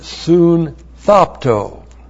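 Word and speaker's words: soon thopto (συνθάπτω): And then we have the verb soon thopto. S-U-N-T-H-A-P-T, long O. Soon thopto.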